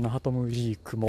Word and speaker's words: Nachtmusik: そのナハトムジークも、えーまあ、そのパターンで読み方が悪かったですねあのカタカナ表記なんでナハトムかと思ったんですよナハトムジーク